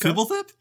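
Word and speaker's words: Fblthp: Flip Flip, the homunculus in Ravnica. – Fibble Flip? Did you mispronounce Fibble Flip? – I did. I'm sorry. The lack of vowels threw me off.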